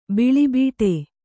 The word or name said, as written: ಬೀಳುಬಿಡು